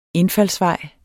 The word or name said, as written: INDFALDSVEJE